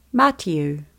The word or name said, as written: Matiu